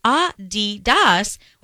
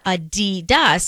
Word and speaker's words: Adidas: And lastly, Americans pronounce Adidas wrong. It's supposed to be Adidas with a slight emphasis on the first syllable, not Adidas, stressing the second.